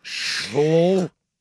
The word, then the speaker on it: Schlittler: And if the Schlittler, right? Did I say that right? The Schlittler? – Yeah, just make sure you hit that L. – And also the emphasis on the Schlittler.